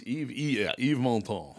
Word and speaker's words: Yves Montand: It's Yves Montand.